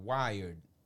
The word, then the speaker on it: Wired: Eh, que ahí la revista Wired, eh, que se escribe W y latina R E D, Wired.